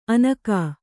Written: ಅನಕಾ

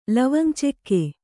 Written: ಲವಂಗಚೆಕ್ಕೆ

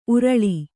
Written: ಊರಾಳಿ

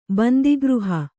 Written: ಬಂದಿಗೃಹ

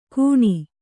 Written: ಗುಣಿ

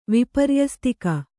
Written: ವಿಪರ್ಯಸ್ತಿಕ

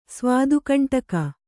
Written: ಸ್ವಾದುಕಂಟಕ

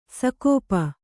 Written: ಸಕೋಪ

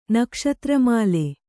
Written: ನಕ್ಷತ್ರಮಾಲೆ

